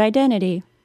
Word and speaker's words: identity: Now let's look at the word identity. There are two T's in that word. If I pronounce them both, it would sound like identity. But few people would say it that way. They would not say the first T at all because it is between an N and a vowel sound.